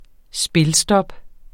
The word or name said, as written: spilstop